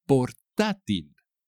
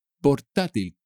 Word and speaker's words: portátil: Portátil. Portátil. Con acento en la A.